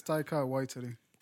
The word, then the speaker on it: Taika Waititi: It's Taika Waititi.